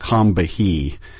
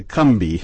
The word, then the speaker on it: Combahee: And by the way, if you see the river on the map, it looks like it should be pronounced Combahee, But I found out in South Carolina they call it the Cumbie River.